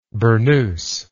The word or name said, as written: burnoused